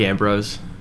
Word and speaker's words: Ambrose: Is it a hard S or a soft S at the end? – It's Yannick Ambrose.